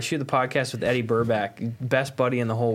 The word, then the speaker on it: Burback: Every time I go to say his name, I gotta say Burbank. – Burbank. – So do I. – It's Burback.